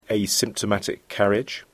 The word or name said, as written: asymptomatic carriage